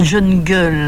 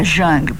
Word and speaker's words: jungle: Attendez, ça n'est pas digne de la République que ces gens, une fois qu'ils sont là, soit dans cette la jungle, la jungle, paraît-il prononcer à l'anglaise jungle, mais c'est pire.